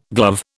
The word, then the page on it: glove: British English